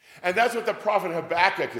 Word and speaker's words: Habakkuk: I asked Reverend Casperson in Sunday school today, how do you say it? He goes, Habakkuk. That's how I always learned it too. With potato, potato, Habakkuk or Habakkuk, you can do either one, just say the name.